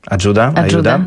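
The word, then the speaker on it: Ajudha: Poppy Ida? Tak się wymawia? – A Judah? To w zasadzie wedle uznania.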